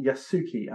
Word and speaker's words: Yasuke: The Incredible Story of Yasuki, and I hope I'm pronouncing that correctly. – Yep, it's good.